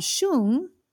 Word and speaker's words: Shoong: And the album single is called Shung, featuring Lisa from Blackpink. It's spelled S-H-O-O-N-G. In the song, I can't hear the N-G very well. It sounds very shoo.